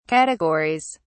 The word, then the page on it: categories: British English